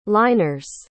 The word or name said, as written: liners